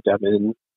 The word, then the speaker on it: Devin: So it's spelled with an I, Devin Lindstrom, then you'll find it there.